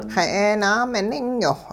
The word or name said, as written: xa'eenaameningyowh